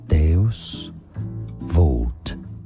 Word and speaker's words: Deus Vult: In English, it's pronounced as Deus Vult, a Christian motto relating to divine providence, meaning God wills it.